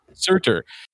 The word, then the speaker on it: Surtur: And the movie starts off with him with, how do you say, it's Surtur? – Surtur.